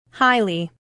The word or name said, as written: highly